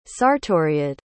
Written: sartoriad

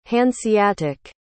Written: hanseatic

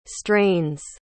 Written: strains

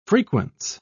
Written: frequence

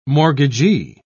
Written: mortgagee

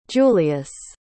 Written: julius

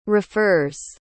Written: refers

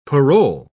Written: parole

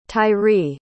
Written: tyree

